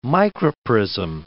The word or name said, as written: microprism